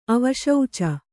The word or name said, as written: ಅವಶೌಚ